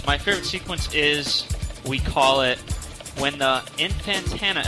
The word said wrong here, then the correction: Infantata: attacks. – Infantata. – Tatas. – Thank you. – You said that wrong. You said Infantana.